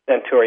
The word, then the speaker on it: santoor: You called it Centaur? – Centaur, yes. It's spelled S-A-N-T-O-O-R.